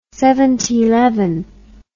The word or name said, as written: seventyleven